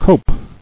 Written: Coped

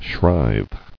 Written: Shrive

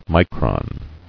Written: Micron

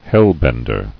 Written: Hellbender

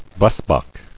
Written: Blesbok

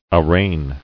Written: Arraignment

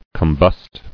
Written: Combust

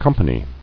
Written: Companied

